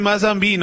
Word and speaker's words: Mazembe: Mozambi. Ojo, se dice Mazembe, ¿no? Mazembe. Gracias, me Mozambi, ¿cómo se dice? – No, pero eso está bien.